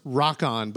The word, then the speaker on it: Rokun: No, it's pronounced Rock-On, but it's spelled Rokun, and it came, I believe it was a Wildstorm or Image.